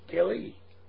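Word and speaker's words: keli: So here, he's talking about the vessel that was made, and vessel there, and the Strong's is 3627, and I think it's pronounced like the word Kelly. Kelly.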